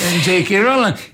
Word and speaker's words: J.K. Rowling: Per esempio, è uscito, è stato pubblicato il primo di una serie di romanzi fantasy scritti da J.K. Rowling. L'ho detto bene? – Sì, J.K. Rowling.